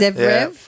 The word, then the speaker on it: Zverev: Zverev, is it? – Zverev.